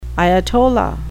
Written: ayatollah

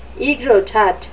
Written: aegrotat

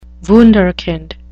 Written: wunderkind

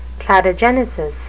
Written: cladogenesis

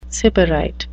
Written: sybarite

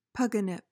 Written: pogonip

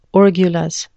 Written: orgulous

